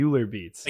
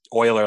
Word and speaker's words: Euler: I've been pronouncing it Euler Beats. – Like Euler Beats, but it's Euler, like the mathematician. His last name's Euler.